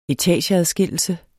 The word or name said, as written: ETAGEADSKILLELSE